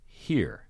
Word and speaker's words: Hir: But only his words are present in a play called Here, spelled H-I-R, but pronounced here.